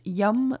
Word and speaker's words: yam: It's spelled Y-A-M and pronounced yum, like yummy food.